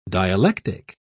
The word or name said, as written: dialectic